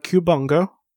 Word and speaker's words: Cubungo: It is the Cubungo. – Cubungo? – Cubungo. – Cubungo. – Yes. I looked at the pronunciation. It's exactly how it's spelled.